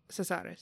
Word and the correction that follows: Casares: Cesares, yeah. Who is great. He's played Casares. – By... Casares. Yes, they pronounce it Casares.